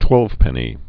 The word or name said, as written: twelvepenny nail